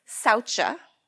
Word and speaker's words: Saucha: So the first one is Saucha, S A U C H A. Sometimes you'll see it spelled without the H.